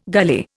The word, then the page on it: gully: Indian